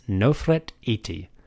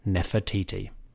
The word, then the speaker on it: Nefertiti: In Egyptian, this name is Nofret Iti. We pronounce it Nefertiti.